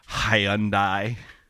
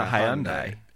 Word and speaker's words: Hyundai: Driving your Hyundai. – You're driving your Nissan. – Ryan already established that he's driving a Hyundai. – Why are you pronouncing it like a British person? – Hyundai?